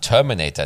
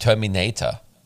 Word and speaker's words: Terminator: Man sagt Terminator, nicht Terminator.